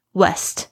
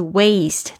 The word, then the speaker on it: waste: West 就会变成西方 W E S T，但是 Waste 它是这个双元音，这个 A 的发音有点像是我们微笑的时候 A，然后后面呢还有一个 E 的结尾。